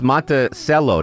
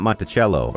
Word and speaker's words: Monticello: And it's Monticello, not Monticello or something like that.